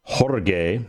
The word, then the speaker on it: Jorge: When George and I, then Sergeant First Class Jorge, that's technically how you pronounce George's first name.